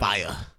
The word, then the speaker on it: Faiyaz: Fire. – Fire. – That's how you say it, man.